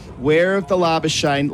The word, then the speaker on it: Labuschagne: Yep, it's Manus Labuschagne, as I said over the loudspeaker, Manus Labuschagne, as we have been conditioned to pronouncing it.